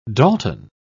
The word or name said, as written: dalton